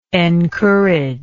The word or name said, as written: encourager